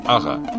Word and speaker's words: Ага: Ударение, как во всех казахских словах, на конце. Ага.